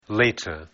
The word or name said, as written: later